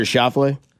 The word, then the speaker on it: Schauffele: Or Xander Schauffele? – Yeah, Schauffele.